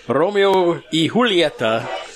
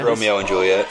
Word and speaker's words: Romeo y Julieta: So this is the Romeo y Julieta. – Yeah. – That's the proper pronunciation. – Yeah, not Romeo and Juliet.